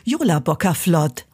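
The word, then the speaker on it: Jólabókaflóð: Also, das ist tatsächlich eins der einfachsten Worte, die es gibt. Jola Bockerflott. Also man spricht es tatsächlich fast, wie man es schreibt, und das ist ein Wunder im Isländischen und Deutschen.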